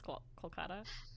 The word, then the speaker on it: Kolkata: Hyderabad, Kolkata? – It's Hyderabad, and it used to be Calcutta, and now it's Kolkata.